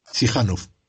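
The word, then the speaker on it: Chișinău: ¿Cómo se dice Chichinau? – Sihanov.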